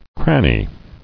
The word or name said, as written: Crannied